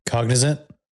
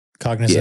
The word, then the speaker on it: Cognizant: Like one of the big sponsors for Aston Martin, I believe, the Formula One team, is Cosignat or something like that. It's COZ. I don't know if I'm saying it right. Yeah. Cognizant. – Cognizant.